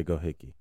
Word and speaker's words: Gohike: But man, if y'all don't know about my man's Jack Gohicke, I think I'm pronouncing his name right. Gohicke. Gohicke. Gohicke. G-O-H-I-K-E. Gohicke. We're going to say Gohicke.